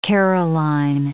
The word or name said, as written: Caroline